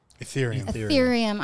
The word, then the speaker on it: Ethereum: Is it Ethereum? – Ethereum.